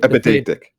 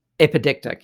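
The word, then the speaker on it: epideictic: Where the, remind me how to say it, is it epistemic? No. – Epidictic. – Epidictic, yeah.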